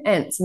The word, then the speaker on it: Ence: People are like, what's your last name? Ence. And they're like, how do you spell it? E-N-C-E. They're like, N-C? And I'm like, no. Almost. But the E is silent, like at the end of my name.